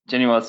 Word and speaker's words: Genoa: You always want to put that U in there. Genoa. No. You got it.